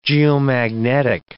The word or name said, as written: geomagnetic